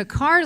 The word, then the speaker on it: Kerr: And just recently, Billups' party of six, we took a family vacation to Carr Lake, although it's spelled Kerr, but Carr Lake in Henderson, North Carolina.